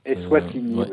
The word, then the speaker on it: Eswatini: Qu'est-ce qui se passe dans le petit état de d'Eswatini, si je prononce bien Eswatini,